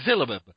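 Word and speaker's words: Fyxxilab: So next up, on August 23rd, Belgian Garrison was requested by Fixalab, which I'm assuming that's how it's pronounced. You folks can't see this, but it's F-Y-X-X-I-L-A-B. L-A-B. – L-A-B. – I think I got the lab part. – Fixalab. It's Fixalab.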